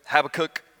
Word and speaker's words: Habakkuk: So whether it's Habakkuk or Habakkuk, you might even hear me go back and forth, but I'm going with Habakkuk because it rhymes with book.